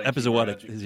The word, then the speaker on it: epizootic: Mesozoic hemorrhaging disease. Is that how you pronounce it? – Epizootic.